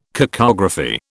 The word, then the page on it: cacography: British English